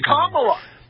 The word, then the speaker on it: Kamala: And by the way, just stop mispronouncing her name because they're going to start calling you a racist. Not that they don't already do. – No, it's Kamala.